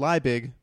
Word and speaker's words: Liebig: Welcome to the party, Jason Liebig, spelled Liebig, but pronounced Liebig. – But pronounced Liebig. That's the German pronunciation.